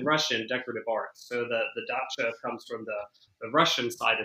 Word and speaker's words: dacha: And some really unusual attractions, like the Russian, is it a dacha? Am I saying it correctly? – Yeah, well, hey, that's not my world of things, but I say dacha.